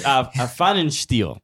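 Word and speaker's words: Pfannenstiel: We talked to Lutz Fennenstein. Is that close? – He is so proud he knows how to pronounce that name. – Yeah. It's Fennenstiel.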